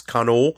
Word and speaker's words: Kano: Kano is sometimes spelled with an H on the end, but sometimes not spelled with an H, but it's a long O, so it's Kano.